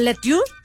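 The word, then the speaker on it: La Tour: Ahora esta morra se llama Erika Letour, o Letour, como se pronuncia en francés, y Phil.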